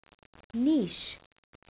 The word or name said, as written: niche